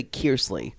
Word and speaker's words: Kearsley: I like it how you always say Cody Kersley. It's Cody Kearsley.